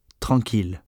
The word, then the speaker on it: tranquille: There are three exceptions where i deux l is pronounced il and not y: mille, ville, tranquille.